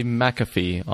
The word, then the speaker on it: McAfee: Does say McAfee on the Wikipedia pronunciation guide.